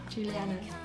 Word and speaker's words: Juliana: It's the Latin pronunciation. Juliana.